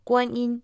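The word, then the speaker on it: Куаньинь (观音): Гуань означает «смотреть», произносится в первом тоне, а инь – это звук, читается тоже в первом тоне. Куаньинь – богиня милосердия и сочувствия.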